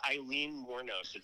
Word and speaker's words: Aileen Wuornos: Yeah, it's Aileen Wuornos.